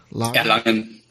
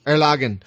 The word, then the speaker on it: Erlangen: Joining us from Erlangen? Did I say that right? Erlangen? Erlangen? Is it A? – Erlangen. – Erlangen. – Yeah, we're pretty close.